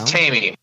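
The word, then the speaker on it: Taime: Is it Timey? Timey. – It's Tamey. I listened to I'm a big fan of Ricky Rackman's Hollywood Cat House Hollywood podcast. So big shout out to Ricky because it's awesome. But he was roommates with Tamey Down, and that's how he pronounces it.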